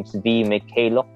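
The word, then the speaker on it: Svi Mykhailiuk: I don't know how to pronounce his name. Svi Mikhailuk or something. – Yep. Yep, that's right.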